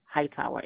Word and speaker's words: Hightower: name, Hightower, HIGHTOWER.